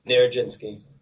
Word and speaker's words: Nierodzinski: Nierodzinski.